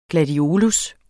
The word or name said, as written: GLADIOLUS